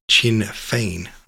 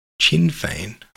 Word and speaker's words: Sinn Féin: I even have some grasp of the Irish conflict and a political party, Sinn Féin, helping you out here. It's pronounced like Chin Féin. Oh, thanks. Chin Féin.